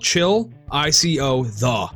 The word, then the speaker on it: Chillicothe: It's literally spelled Chill I-C-O-the.